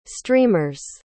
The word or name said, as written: streamers